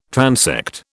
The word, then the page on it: transect: British English